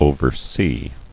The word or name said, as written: Overseen